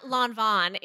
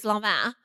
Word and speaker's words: Lanvin: It's not Lanvin, it's Lanvin.